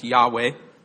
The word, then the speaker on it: Yahweh: The name he revealed to Moses, we pronounce as Yahweh.